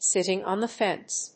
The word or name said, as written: sitting on the fence